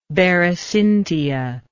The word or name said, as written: berecyntia